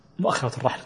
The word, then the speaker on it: مؤخرة الرحل: طبعا قوله كمؤخرة الرحل، بعض الناس يشدد الخاء، وبعضهم يقول إن الخاء تشديدها لحن، نقل ذلك ابن قايد، ولكن هكذا يعني موجودة في كتب الفقه ونطقهم لها.